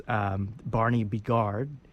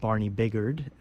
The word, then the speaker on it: Barney Bigard: I learned it's Barney Bigard, not Barney Biggard, as you might assume it's.